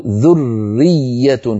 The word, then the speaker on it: ذرية: ذرية، أيود أحدكم أن تكون له ذرية فيها راءان مشددان أو مشددتان، الحروف تذكر وتؤنث، وفيها ياءان مشددتان، ذرية، فلا بد من بيان الراءين ومن بيان الياءين. ما نقول ذرية ذرية هكذا نخطفها أو وكأننا نطقنا راء واحدة، ذرية. نعطي كل حرف حقه.